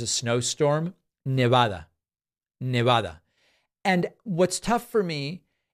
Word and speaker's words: Nevada: And then Howie says, love your stuff, but it's pronounced Nevada, not Nevada.